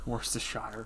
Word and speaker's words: Worcestershire: I'm trying to figure out how to say it. – Worcestershire. – Worcestershire. You mean like the sauce? – It's spicy.